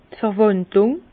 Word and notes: d' Verwàndlung: Bas-Rhin; Herrlisheim

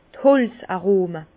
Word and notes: d' Holz-Arome: Bas Rhin; Herrlisheim

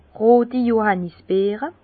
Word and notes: roti Johànnisbeere: pl.; Bas Rhin; Herrlisheim

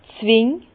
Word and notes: d' Zwing: Bas-Rhin; Herrlisheim

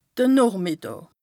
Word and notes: de Noochmìddàà: Bas-Rhin; Strasbourg

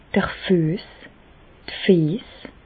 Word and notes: d'r Füess, d' Fiess (pl): Bas-Rhin; Reichshoffen

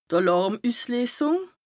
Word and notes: Àlàrmüssleesùng: Bas Rhin

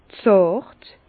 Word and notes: zàrt: Bas-Rhin; Herrlisheim

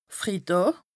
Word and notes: Fridàà: Bas-Rhin; Strasbourg

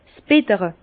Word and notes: 's Bittere: Bas-Rhin; Herrlisheim

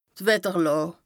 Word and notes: Wetterlàà: Bas Rhin

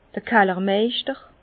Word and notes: d'r Kallermeischter: Bas-Rhin; Herrlisheim

